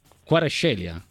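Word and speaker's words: Kvaratskhelia: Quarascelia.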